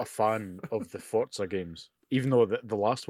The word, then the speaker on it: Forza: Same with the new Forza game. No, sorry, we're mispronouncing it again. God damn it, we talked about this beforehand. Forza. The Forza game with the Forza.